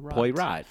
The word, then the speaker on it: Poirot: Poirot, which I'm very sure that that's the correct way to pronounce it.